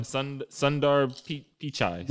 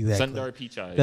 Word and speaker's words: Sundar Pichai: Sundar Pichai. – Yeah, exactly. – Sundar Pichai.